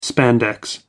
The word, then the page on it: spandex: American English